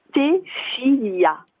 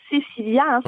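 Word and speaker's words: Stéphylia: Et comment comment je l'ai créé Parce que ma fille s'appelle, alors je le dis bien, Stéphilia. – Stéphilia. – alors c'est pas Cécilia, hein, Stéphilia. – Stéphilia, comme Stéphanie au début, quoi. – Voilà, S-T-E-P-H-Y-L-I-A.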